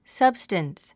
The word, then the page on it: substance: sʌ́bst ə ns; サブスタンス